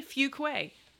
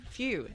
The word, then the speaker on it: Fuquay: See, I said it right? – Yes, you did. – Woohoo. Very good job. I have learned. Fuque. F-E-W. It's not spelled that way. Spell it for us, though. – F-U-Q-U-A-Y. – What is that? – It's French.